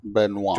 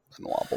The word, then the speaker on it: Benoit: the inquisitive and debonair Detective Bonnet Blanc. How do you say his name? – Benoit. – Benoit Blanc. – Benoit Blanc.